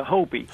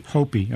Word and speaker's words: Hopi: but is it the Hopi Indians? – Yeah, Hopi. – Hopi, okay, yeah.